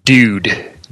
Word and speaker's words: DIWD: And in addition, its name, the cool kids pronounce it Dude. D-I-W-D. – D-I-W-D. Dude.